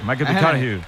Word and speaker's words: McConaughey: Matthew McConaughey. – Sí. Ya, hay que ir corrigiendo la pronunciación. – McConaughey.